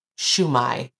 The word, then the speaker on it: Shema: The Shema, or Shumai, depending on how you pronounce it.